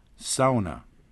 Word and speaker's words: sauna: Yeah, I think most Americans simply look at it and see the AU combination and treat it as if it were an English word, and so they call it sauna, as they would say haunted or something like that.